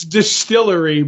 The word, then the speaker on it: DSTLRY: From an unpronounceable comic company name, Distillery, D-S-T-L-R-Y. – It's Distillery, but no vowels.